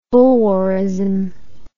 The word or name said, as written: boulwarism